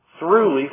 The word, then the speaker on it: throughly: T-H-R-O-U-G-H-L-Y. Is that, did I just spell that right? I think I did. Truly furnished. I've probably told you this before, but I'm going to tell it to you again. It sounds very much like thoroughly, and that's what your new versions are going to say.